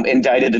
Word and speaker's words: indict: We have a former president who has been indicted a number of times, and there are trials ongoing, as you know. But, of course, indict also has that very unusual silent C.